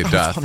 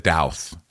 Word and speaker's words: doth: No, gosh, he didn't say doth, he said douth. Shep is douth.